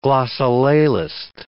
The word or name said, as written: glossolalist